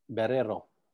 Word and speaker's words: Barrero: It's It's B-A-R-R-E-R-O. – Oh, Barrero. – Barrero. The Americanized way to say it.